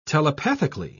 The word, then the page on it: telepathically: British English